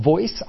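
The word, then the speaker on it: Voyc: Yes, it's pronounced VOICE, standing for Voice of Your Customer.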